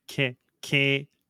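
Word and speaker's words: Kehler: It's the Canadian pronunciation, so there's an E-H in the name, which is pronounced A, so that is Bud Keller. – So it's Kahaler. – It's Kahaler.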